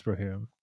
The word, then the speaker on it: Rohirrim: Yeah, like that part at the, you know, in Return of the King, where Aemir is like Rohirrim, like, yeah, it's Rohirrim. But anyway, I've been saying it wrong for a long time.